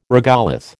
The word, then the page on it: regolith: American English